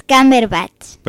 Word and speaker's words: Cumberbatch: El apellido de Benedict. – Es Cumberbatch. – Impecable.